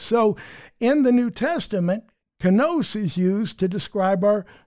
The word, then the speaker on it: kainos: However, the word that's used for the new wineskins is kainos. We would spell it K-A-I-N-O-S.